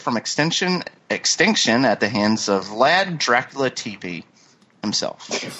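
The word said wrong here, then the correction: Țepeș: Tepish? – Tepish. – You're right, I'm sorry. It's pronounced... – You're right. – It's pronounced like TS, it's tepish.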